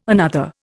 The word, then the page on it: another: Indian